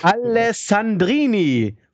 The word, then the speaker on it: Alessandrini: Laut L'Equipe ist der BVB an Romand Alessandrini von Startrennen interessiert. – Also erstmal Respekt, wie du das ausgesprochen ja. hast. Das klang echt richtig.